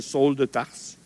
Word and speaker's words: Saül: Paul est alors appelé Saül, Saül de Tarse. Il y en a qui vont dire Saul de Tarse. C'est une question de prononciation. Je ne vois pas d'erreur ni dans un ni dans l'autre,